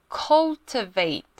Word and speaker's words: cultivate: 再来呢就是 cultivate，应该是 t，那我们一样把它念成 d 的音 cultivate，coal 有一个很像那个煤那个 coal。